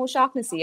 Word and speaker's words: O'Shaughnessy: But like cannabis was introduced to the Western world by an Irish physician named O'Shack Hennessy. Now, I believe he pronounced it. You're right, He pronounced it O'Shaughnessy, not O'Shack Hennessy. That's just ridiculous.